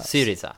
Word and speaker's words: Syriza: Hur uttalar man den på svenska? – Syriza. – På svenska? – Ja, men whatever. Ja. Med svensk dialekt. Ja. Syriza.